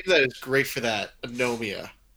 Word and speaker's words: Anomia: uh, A N O M I A. Anomia.